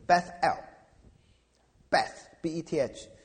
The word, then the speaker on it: Beth-El: 因为伯特利这个字哈，它的希伯来语的念法是 Beth-El，Beth-B-E-T-H，这个意思是家，Beth-El，El 是神的意思，阿拉伯人就念成阿拉。